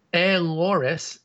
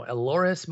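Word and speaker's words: Elehuris: Eloris. It's E-L-E-H-U-R-I-S Montero. Eloris Montero.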